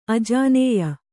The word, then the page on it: ಆಜಾನೇಯ: ājānēya